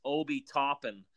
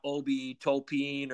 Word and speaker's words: Obi Toppin: So for the record, it's Obi Toppin. Okay, not Obi Topin or Topin, which was what we called them.